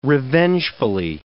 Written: revengefully

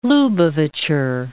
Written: Lubavitcher

